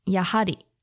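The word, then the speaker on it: やはり: Finally, the last thing you need to know is about different ways you can pronounce yappari. One is yahari and is the more polite form, usually used by service staff or when talking to someone of a higher social rank than you, slash someone older.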